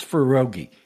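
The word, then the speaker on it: Faruqui: And they did this until one of the magistrate judges, a guy by the name of Zia Farugi, and his last name spelled F-A-R-U-Q-U-I. I've listened to a bunch of things on YouTube, how to pronounce it. I believe it's Farugi is his name.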